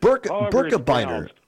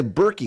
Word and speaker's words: Birkebeiner: Hey, by the way, I wanted to ask you, is that, how do you pronounce it, the Birkebeiner, the Birkebeiner? – Birkebeiner, yeah. I assume it's Swedish. Hey. Norwegian. – Yeah. Oh, okay. – So, yeah, Birkebeiner. Some people say Berkey. Oh. I thought it was Birkebeiner, but I think most people here pronounce it Birkebeiner. – We'll just go with Berkey.